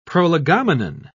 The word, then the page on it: prolegomenon: British English